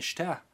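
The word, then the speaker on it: mishteh: They usually accent, unless they show you otherwise, the last syllable. So okay. – It would be mishtah.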